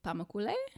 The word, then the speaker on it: Pamukkale: And then after that, I want to go to Pamukkale. I looked it up. And that's how you say it.